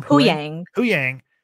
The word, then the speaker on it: Huyang: And that's like Huang's or who, how do you say his name? – Huyang.